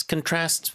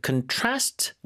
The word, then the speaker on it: contrast: 자, contrast with 뒤에 강세를 주로 많이 주고 있습니다. 발음이요. – Contrast. – 동사 읽을 때는 네. – 이게 명사일 땐 contrast 앞에 주거든요. – Yeah. – 동사일 때는 강세가 뒤로 넘어간다는 거.